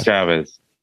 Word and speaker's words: Chavez: Chavez. I said it right.